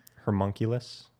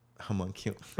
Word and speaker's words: homunculus: Hermunculus? – Homunculus.